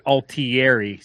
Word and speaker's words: Altieri: Italian last name. Yep. So if you're a Sopranos fan, there was actually an Altieri, but they pronounce it Altieri. But anyway. – And the correct pronunciation is Altieri.